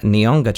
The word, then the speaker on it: Nihonga: Forgive me if this pronunciation is wrong, but the Nihonga tradition.